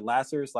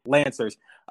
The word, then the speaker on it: Lancers: And can you just paint a picture of the Omaha Lancers, or is it Lassers? Like, how do you say it? – Lancers.